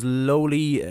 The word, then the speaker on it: Lōwli: So, Róisín Lowry, otherwise known as Lowly, Lowly, am I pronouncing that right? – Yeah, perfect.